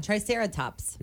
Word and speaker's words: Triceratops: Yeah, Triceratops.